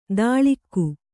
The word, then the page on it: ದಾಳಿಕ್ಕು: dāḷikku